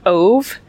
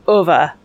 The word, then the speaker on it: Ove: And I always assumed that it was A Man Called Ove, which is what Hallie said. But Ova, I guess, is how you pronounce that name.